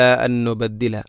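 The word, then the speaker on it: അൻ നുബദ്ദില (أَن نُّبَدِّلَ): അത് വേറെ വേറെ ആയിട്ട് ഓതേണ്ടതില്ല. ഓതുമ്പോൾ അത് കൂട്ടി ലയിപ്പിക്കുകയാണ് എന്നുള്ള ആ ഒരു നിയ തന്നെയാണ് വേണ്ടത്. അല അൻ നുബദ്ദില, അൻ നുബദ്ദില.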